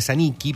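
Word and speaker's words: Zanicchi: Es Iba Saniki, eh, claro, eh, en el italiano se pronuncia Saniki, pero sería Sanichi, Z, con doble C, H-I.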